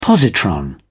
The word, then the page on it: positron: British English